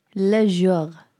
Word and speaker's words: le jour: Le jour.